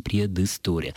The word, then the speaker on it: предыстория: Вслед за произношением мы произносим здесь «ы». Мы не можем сказать «предыстория», это будет звучать фальшиво, поэтому и пишем «предыстория». Исключением являются приставки иноязычные, но, собственно говоря, там и произношение идет.